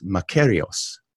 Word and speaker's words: makarios: The word he uses for happiness in the ancient language of the Bible is a word that's pronounced makarios, makarios.